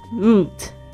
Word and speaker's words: route: Route）。这个 Route。也可以念成 route，好，就是看就是你自己的习惯。